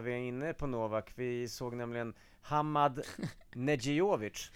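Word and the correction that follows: Medjedović: Medjedovic. – Vad fan. – Svårt uttal. Medjedovic, va? Eller?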